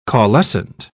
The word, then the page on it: caulescent: British English